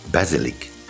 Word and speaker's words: basilic: Basil in French is pronounced basilic and spelled B-A-S-I-L-I-C.